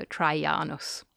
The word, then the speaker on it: Traianus: but the Romans pronounced it as Y, so Traianus.